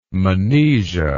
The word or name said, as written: monesia